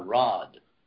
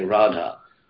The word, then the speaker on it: Radha: You can't call Radha Rod. I mean, you got to say Radha. Rama is called Ram, but you can't call Radha Rod. So you can't drop a long A.